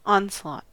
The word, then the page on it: onslaught: American English